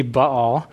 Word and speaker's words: Baal: So Baal, and I've heard it pronounced other ways, Baal, of hay or something like that, but it's, as far as I know, it's supposed to be Baal, but we can disagree if you want.